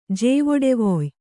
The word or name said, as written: ಜೇವೊಡೆವೊಯ್